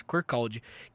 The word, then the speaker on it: Kirkcaldy: É Provavelmente é o nome da, da, da, da cidade, a pronúncia é essa, Corcalde, que é, que é a cidade.